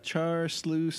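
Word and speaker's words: Char siu (叉燒): That's like SIU is like shu, but the pronunciation is it's Chinese.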